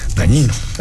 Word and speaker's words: Dagnino: Es que en, italiano y en francés la es... se pronuncia ñ. – Como lasaña. – Dañino.